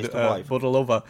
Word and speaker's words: Bohdalová: From my end, to Irina Bohdalova, but yeah. Absolutely butchering the last name here, but... – no, you got it pretty good, Bohdalova.